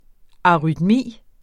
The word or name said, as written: arytmi